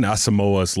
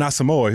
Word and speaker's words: Asamoah: Brian Asamoah's last name is pronounced Asamoah. – Yeah, like Namdi Asamoah, the old Eagles corner. – I recorded a Viking circle with him, and I was like, Brian Asamoah.